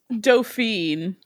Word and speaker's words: Dauphine: You're, I believe it's Dauphine. – Dauphine? Sorry, Dauphine. – It is pronounced more like how it looks than you would think.